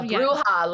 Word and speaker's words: Bruja: Bruja, like Brujeria.